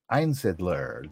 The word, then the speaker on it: Einsidler: We're just going to put a longer I on the on the I and go EINSIDLER.